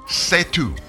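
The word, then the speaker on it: C'est tout: It's called CETU. That's spelled capital C, apostrophe, E-S-T, new word, T-O-U-T. It's French for that's all, folks.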